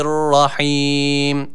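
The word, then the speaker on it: rahim (رحيم): Selamun kavlem min rabbir rahim. – Rahim kelimesi de rahim şeklinde okunuyor. Yani yine burada mide boşluğundan gelen H olarak okunuyor. Dikkat ediyoruz, burada H harfi yok. H harfi var, esrelidir.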